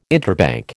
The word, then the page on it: interbank: American English